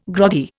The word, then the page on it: groggy: Indian